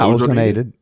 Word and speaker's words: ozonated: How would you say that? Ozone. – Yeah, ozonated. Yeah, very well said. Water. Yeah, you did well.